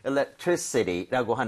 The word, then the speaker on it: electricity: So electricity라고 하는 거, I에다 강세를 찍고 이렇게 발음을 하게 되면 전기라는 명사가 될 수가 있습니다.